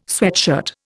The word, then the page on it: sweatshirt: Indian